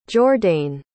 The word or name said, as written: jordain